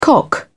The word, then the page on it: cock: British English